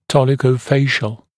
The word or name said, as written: dolichofacial